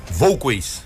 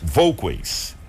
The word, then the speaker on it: Volquez: Presidente, primeiramente bom dia, é um prazer recebê-lo aqui pela primeira vez nos estúdios da 93 FM Elbio, eu queria que você dissesse agora como que se pronuncia corretamente seu sobrenome. Bom dia a todos. Volquez. Volquez. Elbio Isso. Volquez.